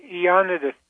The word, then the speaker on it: Ioannidis: John, how do you say his last name? Ioannidis? – Yeah, Ioannidis.